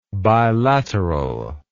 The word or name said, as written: bilateralness